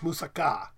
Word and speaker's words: moussaka: Anyway, it's in Greek, it should be pronounced moussaka, with an emphasis on the last K-A.